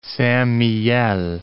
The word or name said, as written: Saint-Mihiel